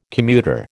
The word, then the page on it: commuter: American English